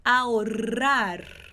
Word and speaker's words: ahorrar: E a pronúncia desse R que está entre o O e o A, tá bom? Principalmente. Então, ahora, que é agora, e ahorrar. E aí, esse R duplo vai vibrar e o R no final da palavra também vai vibrar.